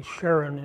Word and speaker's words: Sharon: We say Sharon in English, and Hebrew is actually pronounced Sharon, like Ariel Sharon, the former defense minister, former prime minister of Israel, Ariel Sharon.